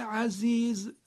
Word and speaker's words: عزیز: کلمه عزیز زه فارسیه، یعنی همون جوری که ما زه رو در فارسی تلفظ میکنیم، اینجا هم کلمه عزیز زش باید همون جوری تلفظ بشه.